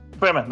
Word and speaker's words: Fremen: Fremen, that's it.